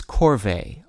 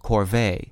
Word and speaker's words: corvée: The word of the day for June 11th is corvée, also pronounced with the stress on the second syllable, corvée, and spelled C-O-R-V-E-E.